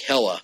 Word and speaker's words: Hela: Hela.